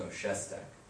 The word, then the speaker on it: Shestack: S-H-O-S-T-A-K? – E-S-T-A-C-K. – Wait. – S-H-E-S-T-A-C-K. – Shestak, it should be. And you pronounce it Shestak? – No, Shestak.